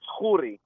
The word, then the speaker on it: Khoury: The real Israeli Middle Eastern way, it's Khoury, but you'll be fine with Khoury.